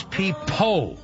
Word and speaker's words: Peapo: His real name is P. Po. P-E-A-P-O.